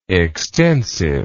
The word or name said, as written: extensivity